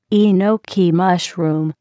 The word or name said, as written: enoki mushroom